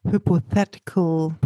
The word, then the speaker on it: hypothetical: And the other thing, what I personally find most difficult about the if clause too, is this hypothetical pronunciation. Can you pronounce that correctly for me, please? – Hypothetical. – Hypo, not hippo. Hypo.